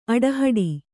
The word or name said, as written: ಆಟಹೂಡು